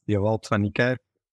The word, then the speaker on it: Dewald van Niekerk: You know, it's Dewald van Niekerk.